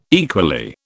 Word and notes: equally: British English

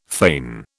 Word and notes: feign: British English

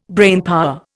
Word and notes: brainpower: Indian

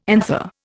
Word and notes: anther: Indian

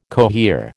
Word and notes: cohere: American English

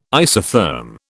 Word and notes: isotherm: British English

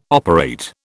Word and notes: operate: British English

